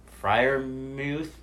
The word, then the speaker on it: Freiermuth: Yeah, and going back to that Steelers offense, I think the second-round pick out of Penn State, Pat Freiermuth? – Yeah.